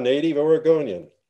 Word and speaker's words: Oregonian: And he's a native Oregonian. What is that? Am I saying that right? – Yeah, native Oregonian.